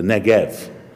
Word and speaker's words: Negev: And that's because, well, in Hebrew, it's actually a soft B, which is pronounced as V, the Negev.